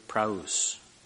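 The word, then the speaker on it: praus (πραΰς): and it's from the root word praus. I'll spell it. P-R-A-U-T-E-S. P-R-A-U-T-E-S. And it's from the root word P-R-A-U-S.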